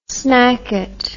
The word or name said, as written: snackette